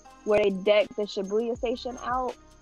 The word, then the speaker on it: Shibuya: They literally change, what is it, Shibuya? Is that how you pronounce it? – Yeah, the Shibuya.